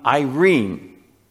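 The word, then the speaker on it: Irene (εἰρήνη): This word peace uh, is uh, if you were to pronounce it in the, uh, the Greek word in English, it's basically the name Irene.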